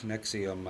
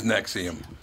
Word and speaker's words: NXIVM: How do you say that? Nexium. – Oh, it's Nexium. Ne- oh it is Nexium.